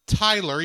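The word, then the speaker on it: Tylor: Because it is Tyler, even if it's spelled odd for Tyler, but yeah.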